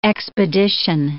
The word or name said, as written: expedition